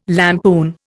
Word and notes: lampoon: Indian

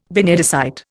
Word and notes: benedicite: Indian

